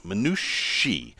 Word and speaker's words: Minutiae: I have been playing with this new app called Minushi, which is the plural of minutia. And that is spelled Minutiae, Minushi.